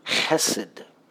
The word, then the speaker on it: chesed: It's the word chesed. All right, you get the little sound at the beginning there. You can spell it H-E-S-E-D.